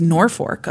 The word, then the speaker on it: Norfolk: It was the place in Nebraska. It was Norfolk, apparently. – But it has an L in it.